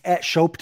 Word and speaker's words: Schopp: I don't even know if he ever meant to, it's @ Schopp Talk, but I'm wondering if Schopp people, his name is pronounced Schopp, but now that you say Schopp Talk, it sounds like it should be Schopp Talk, but right.